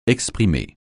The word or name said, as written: exprimer